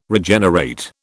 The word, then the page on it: Regenerate: British English